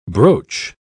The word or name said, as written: broach